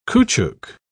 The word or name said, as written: caoutchouc